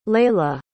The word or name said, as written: leyla